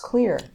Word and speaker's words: Xlear: Which is pronounced clear, even though it's X-L-E-A-R.